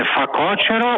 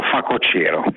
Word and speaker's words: facocero: Poi c'è una cosa che sono anni che non riesco a pronunciare bene, si dice facocero o facocero? – Questa è una domanda interessante perché io credo che tutti dicano facocero, ma in realtà si dica facocero.